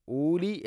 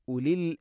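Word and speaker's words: ഉലി (أُولِي): അതുപോലെ തന്നെ ഉലി എന്നാണ്, ഊലി എന്ന് ഒരിക്കലും പറയരുത്. വാവിൻ്റെ മേലക്കാണ് സുക്കൂൻ, അത് ഉച്ചരിക്കുകയില്ല എന്നുള്ളതിനെ സൂചിപ്പിക്കുന്നതാണ്. യാ ഉലിൽ അബ്സാർ, യാ ഉലിൽ അബ്സാർ എന്നാണ്.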